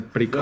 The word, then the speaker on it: Precog: Así se escribe, Precog. Y se pronuncia Precog. – ¿Los Precog? – Exactamente.